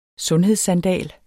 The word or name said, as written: SUNDHEDSSANDAL